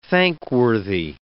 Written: thankworthy

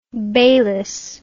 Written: Baylis

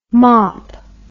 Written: mopp

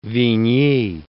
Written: Vigny

